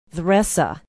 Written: theresa

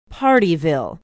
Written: Pardeeville

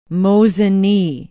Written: Mosinee

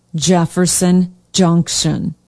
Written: jefferson junction

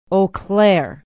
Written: eau claire

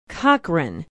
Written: cochrane

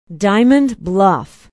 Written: diamond bluff

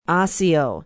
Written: osseo

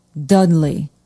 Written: dudley

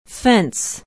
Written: Fence

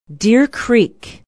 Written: Deer Creek

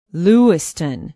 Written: Lewiston

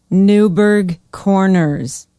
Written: newburg corners

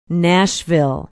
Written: nashville

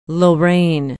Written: lorain

